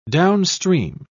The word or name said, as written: downstream